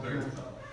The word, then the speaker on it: Obergefell: But in 2015, the Supreme Court ruled in, I can't pronounce the Obergefell, is that Obergefell? Yeah, right.